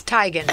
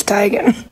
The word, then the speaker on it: Teigen: Chrissy Teigen. I hadn't heard Teigen. – It's. I thought it was Teigen. – No. – Yeah. – It's Teigen.